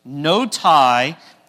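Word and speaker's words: Notae: And so today, the title is No Tie, T-A-E says tie, Ecclesiae. And again, the A-E on the end makes it a long I sound. So say this with me, if you would. No tie, Ecclesiae.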